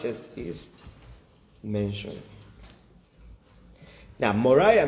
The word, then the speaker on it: Moriah: What does Moriah rhyme with? Moray.